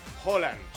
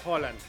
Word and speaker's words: Haaland: Bueno, Alfredo González, comentarista de Fórmula Deportes, ¿cómo se pronuncia? – Holland. – Holland. – Es con O. – Y es rápido, es Erling Haaland.